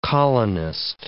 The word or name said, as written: colonist